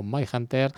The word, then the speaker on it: Mindhunter: Vale, pues vamos con esta canción del grupo Mindhunter.